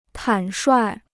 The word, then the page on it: 坦率: tǎn shuài